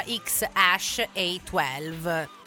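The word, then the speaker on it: X Æ A-12: Il figlio si chiama XEA12, si pronuncia XASH A12.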